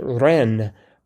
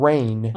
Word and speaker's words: reine: Um, R E I N E, which is pronounced Ren, not not rain.